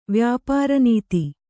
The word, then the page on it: ವ್ಯಾಪಾರನೀತಿ: vyāpāra nīti